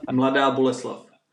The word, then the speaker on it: Mladá Boleslav: Mladá Boleslav.